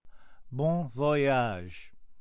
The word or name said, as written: bon voyage